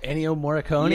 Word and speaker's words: Ennio Morricone: Oh, Ennio Morricone.